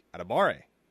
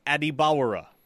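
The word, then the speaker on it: Adebawore: He introduced himself with a pronunciation that, you know, if you listen to it over and over and over again, you would have thought it sounds like Adibare. But obviously, I actually asked him after the game, and then Mike Houck tweeted out the video of him pronouncing his own name. Yeah. It is Adibawara.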